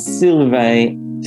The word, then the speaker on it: Sylvain: So it's Sylvain Tripanier. So it's S-Y-L-V-A-I-N.